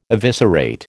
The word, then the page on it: eviscerate: American English